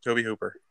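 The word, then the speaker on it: Tobe Hooper: You bet, Toby Hooper.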